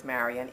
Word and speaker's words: Marion: My name is Marion Elizabeth Lorman Hope. And first name is Marion, M-A-R-I-O-N. Last name, L-A-R-M-O-N-D, for Lorman Hope, H-O-P-E.